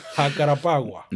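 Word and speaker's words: Jacarepaguá: From 1978 to 89, the Brazilian Grand Prix was held in Rio de Janeiro on a track named Jacarapagua, something like that. I don't know. I know I butchered that for sure. – Jacarapagua.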